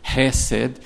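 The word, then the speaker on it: hesed (חסד): Então, compaixão, ser gracioso, ser tardio em irar-se, mas parece que a pronúncia certa é hesed,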